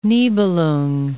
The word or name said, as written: Nibelung